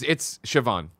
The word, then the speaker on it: Siobhan: You know how bad I am with it, but like watch the HBO show Succession, and Siobhan's it's spelled the same way, but it's Siobhan, and they call her Shiv. So it's like that's how you get it. It's a V instead of a B in there.